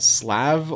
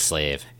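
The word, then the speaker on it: SLĀV: So the thing in question is a musical performance called Slav or Slave. – No, it's Slave. – It's spelled S-L-A-V, but there's a squiggly line over the A.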